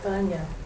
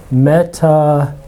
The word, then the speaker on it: metanoia: So this is sometimes, people will pronounce it like this. Metanoia. Like that. Metanoia. But in modern Greek pronunciation, this is an E sound. So you just say, and this is where the accent is. So you say metanoia.